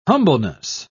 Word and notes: humbleness: British English

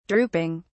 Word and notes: drooping: British English

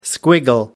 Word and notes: squiggle: American English

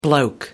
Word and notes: bloke: American English

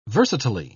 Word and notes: versatilely: British English

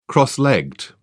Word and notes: cross legged: British English

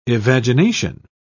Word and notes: evagination: British English

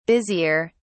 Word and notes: busier: British English

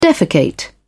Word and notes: defecate: British English

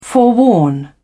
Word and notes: forewarn: British English

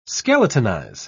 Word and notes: skeletonize: British English

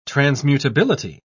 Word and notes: transmutability: British English

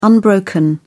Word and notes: unbroken: British English

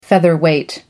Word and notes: featherweight: American English

